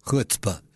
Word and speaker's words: chutzpah: You know what I mean when I say chutzpah, right? That funny word, chutzpah. At first it was a Yiddish word. Now it's in many languages, but still most often pronounced chutzpah. It means nervy, very, very nervy.